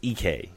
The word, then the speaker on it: Ike: I don't even know what his first name EK. It's EK. – How do you spell it? – Like Ike.